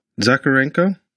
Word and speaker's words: Zakharenko: And my last name is Zakharenko. The H is silent. – Zakharenko? – Yeah, you got it.